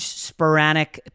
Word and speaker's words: Spiranac: Paige Spiranac.